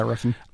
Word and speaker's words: Refn: Is it Nicholas, how do you say his name? Riffin? – Yeah, Refn. Nicholas Winding Refn.